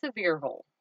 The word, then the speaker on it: Sevierville: Yes, if you look it up, it looks like you would pronounce it Sevierville or whatnot, but it is Sevierville.